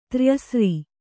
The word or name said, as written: ತ್ರ್ಯಸ್ರ